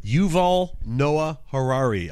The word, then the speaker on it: Yuval Noah Harari: Yuval Noah Harari.